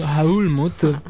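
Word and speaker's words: Älmhult: Ma si dice Almut? – Almut, siccome ce l'ha con i due puntini sopra e quindi è chiusa.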